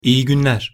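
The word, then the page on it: iyi gunler: Turkish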